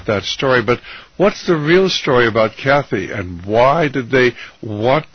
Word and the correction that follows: Cassie: Oh, it's Cassie Burnell, not Kathy. It's just a mispronunciation. – Is Cassie or Kathy? – K-A-S-S-I-E, Cassie.